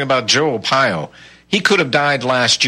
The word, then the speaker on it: Arpaio: your neighbor Joe Arpaio, sheriff, if I pronounced his name right. – Joe Arpaio, yeah.